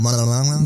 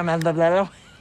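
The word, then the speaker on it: Manganiello: Manganello. – Manganello. – Manganello. – Well, pleasure to meet you, Joe Manganello.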